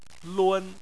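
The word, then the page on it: lun: tone 1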